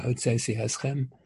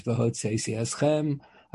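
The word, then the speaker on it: Hotzeisi Eschem (הוצאתי אתכם): And the Pasuk has the expression, Hotzeisi Eschem Mitachas Sivlos Mitzrayim, that's the correct pronunciation, Hotzeisi Eschem.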